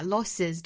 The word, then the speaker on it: losses: 我注意到很多同学会把 losses 这个元音 a 读成一个比较紧张的那个元音就是一个 e 的那个音哈读成 losses 这里面大家注意不是紧音而是那个松音单元音短元音 a says 相当于是 sit sit 里面的那个 a